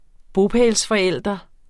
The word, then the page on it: bopælsforælder: -fʌˌεlˀdʌ; -fʌˌεlˀʌ